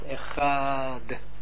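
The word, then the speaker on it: Echad (אחד): Ook moet je ook zo verlengen. Echad.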